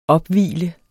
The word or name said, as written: opvigle